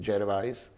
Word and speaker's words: Gervais: Seriál Cancel natočil Uh, Ricky, u nás se říká Ricky Gervais, ale on se jmenuje, on, eh, on sám se vyslovuje, myslím, Gervais, uh, tak uh, natočil seriál Cancel, což byl takový nekorektní nebo velmi zvláštní způsob humoru.